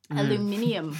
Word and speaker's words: aluminium: And I do think I can taste the aluminum. – Yeah? – Mm-hmm. – What's aluminum? – Aluminium. – Thank you. – That's it. – When you're a guest from our show, you'll pronounce things our way.